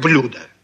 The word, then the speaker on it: блюдо: Ну, неужели вы не поняли, что я нарочно переставил ударение, чтобы вы увидели сразу, как изменяются слова? Грамотные люди, конечно, говорят «блюдо».